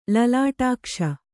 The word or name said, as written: ಲಲಾಟಾಕ್ಷ